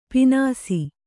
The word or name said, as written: ಪೀನಾಸಿ